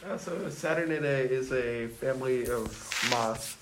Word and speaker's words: Saturniidae: Saturniidae.